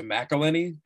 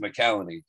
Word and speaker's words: McCallany: I always, in my head, I default to McElhenney because of Rob McElhenney is Mac on Always Sunny. But I think it's spelled different. This is the C-A-L-L says McCallany.